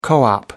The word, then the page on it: co-op: British English